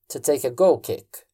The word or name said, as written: to take a goal kick